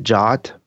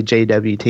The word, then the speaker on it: JWT: The JWT, some people pronounce it JOT, but I prefer to just say JWT because it sounds more, I don't know, it's just how it reads better to me.